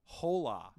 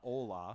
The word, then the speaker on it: hola: playing, and they're like, hola. Yeah. They actually pronounced it hola, not ola. Mm-hmm. It's hola. I love the emphasis on the H.